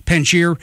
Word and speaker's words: Panjshir: The Panjshir province? – Panjshir province up in the north.